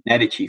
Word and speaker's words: Medici: Medici family, sorry, as I should say it properly.